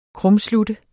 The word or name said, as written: krumslutte